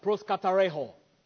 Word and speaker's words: proskartereo: That's the way it's pronounced, proskatareho. P-R-O-S-K-A-R-T-E-R-E-O, proskatareho.